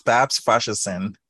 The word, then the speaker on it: Babs Faseesin: It's Babs Faseesin.